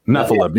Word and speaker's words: Nephilim: Nephilim.